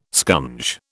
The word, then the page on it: scunge: British English